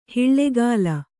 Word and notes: ಹಾಲ್ಗಲ್ಲ: hālgalla

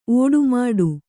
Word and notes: ಓಡುಮಾಡು: ōḍumāḍu

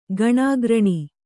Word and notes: ಗಣಾಗ್ರಣಿ: kannada

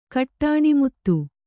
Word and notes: ಕಟ್ಟಾಣಿಮುತ್ತು: Kannada

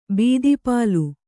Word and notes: ಬೀದಿಪಾಲು: bīdipālu

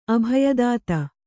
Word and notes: ಅಭಯದಾತೆ: abhayadāte

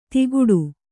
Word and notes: ದಿಗುಡು: diguḍu; kannada

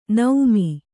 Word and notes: ನೌಮಿ: Kannada

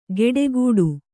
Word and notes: ಗೆಡೆಗೂಡು: geḍegūḍu

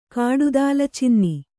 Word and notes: ಕಾಡುದಾಲಚಿನ್ನಿ: kāḍu dalacinni

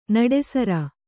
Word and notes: ನಿಟ್ಟುಸಿರು: niṭṭusiru

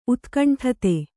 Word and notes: ಉತ್ಕಂಠತೆ: utkaṇṭhate

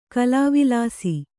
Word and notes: ಕಲಾವಿಲಾಸಿ: kalāvilāsi

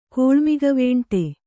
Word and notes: ಕೋಳ್ಮಿಗವೇಂಟೆ: Kannada

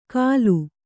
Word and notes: ಕಲು: Kannada

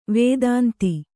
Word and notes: ವದಂತಿ: vadanti

